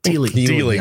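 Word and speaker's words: Dealey: If you go back to Daly Plaza, is that how you say it? Daly Plaza? – Daly.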